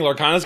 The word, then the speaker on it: Lorcana: Lorcana, L-O-R-C-A-N-A. – Wow. – Lorcana. Like lore and arcana.